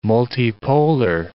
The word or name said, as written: multipolar